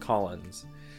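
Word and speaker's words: Caulanz: It's C-A-U... L-A-N-Z. – It's just, believe it or not, it's European. It's just pronounced Collins.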